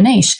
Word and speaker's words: niche: What are they sort of discussing related to your niche? I'm going to say niche because that's how we say it down here. – It's all good. – we say niche rhymes with reach, not niche rhymes with rich.